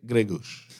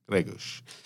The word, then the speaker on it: Greguš: I feel like the the oosh is kind of like it's sort of swallowed a little. It's like when you hear him pronounce, it, it's like Jan Greg, Gre, Greguš, hmm. – Greguš.